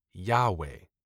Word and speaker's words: Yahweh: It's important to note that written Hebrew doesn't include the spoken vowel sounds, but it is accepted by many today to be pronounced as Yahweh. There's dispute over that pronunciation due to the lack of vowels.